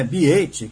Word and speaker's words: B8: Podemos dizer agora que com a nova marca, né, Biate, que se escreve B8, né, avança para ser uma empresa global dedicada ao segmento de energias renováveis?